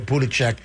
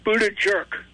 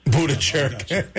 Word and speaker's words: Buttigieg: And by the way, you pronounced it wrong. You pronounced his name wrong. I want you to correct it immediately. Okay? It's pronounced Buttigieg. – Buttigieg.